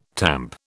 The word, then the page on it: tamp: British English